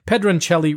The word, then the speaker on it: Pedroncelli: It might be Pedroncelli, but because it's Italian, we're going to pronounce it correctly. Pedroncelli Rosé.